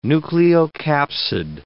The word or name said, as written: nucleocapsid